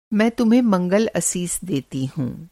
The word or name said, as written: मंगल असीस